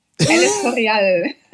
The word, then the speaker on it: El Escorial: El Escorial.